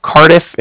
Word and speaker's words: Cardiff: And it's with the Bank of Cardiff. And Cardiff is spelled C-A-R-D-I-F-F, as in Frank, so it's like car and different.